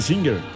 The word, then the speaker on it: Singer: Y la pronunciación oficial debía ser Singer. – Sí, claro. – Sí. Singer. Singer. Sí, es que sí era, sí. Pero le decíamos Singer siempre sí, en los sí.